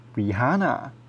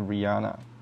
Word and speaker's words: Rihanna: 那今天我们要来闲聊一下蕾哈娜（Rihanna）啊。为什么我要特别讲她中文名字呢？因为，对，因为台湾的翻译啦，就是蕾哈娜。对，那所以很很多人就是会念英文的时候，直接讲 Rihanna，但其实那 H 不发音哦。所以其实正确的念法呢？就是至少在欧美那边，正确的念法是 Rihanna，你跟大家证明一下。